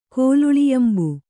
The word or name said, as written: ಕೋಲುಳಿಯಂಬು